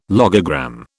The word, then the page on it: logogram: British English